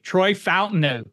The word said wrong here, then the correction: Fautanu: Go ahead, you say it. Say it like a cheesehead. – Fautanu. – Troy Fautanu.